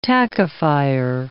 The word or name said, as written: tackifier